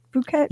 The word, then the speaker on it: Phuket: Is it pronounced Phuket, Thailand? Phuket? – Yeah. – P-H-U-K-E-T? Mm-hmm. Phuket, Thailand? – Hey, it better be.